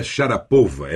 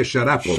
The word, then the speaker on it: Sharapova: Sim, então não seria Maria Sharapova, é Maria Sharapova. – Sharapova, exatamente.